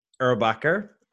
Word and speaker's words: Erbacher: Erbacher.